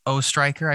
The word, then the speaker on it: Oestreicher: Well, I am excited to be having a conversation with the Mark Oestreicher.